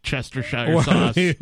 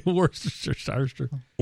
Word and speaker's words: Worcestershire: That is some of the worst Cheshire sauce. – Worcestershire.